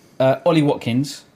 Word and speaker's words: Ollie Watkins: They've got Ollie Watkins.